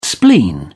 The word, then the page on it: spleen: British English